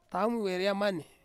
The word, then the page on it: taumi weriamani: Ansus